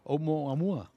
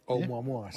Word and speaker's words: Oumuamua: Me parece que pronuncio bien, ¿no? Oumuamua. – Oumuamua, sí.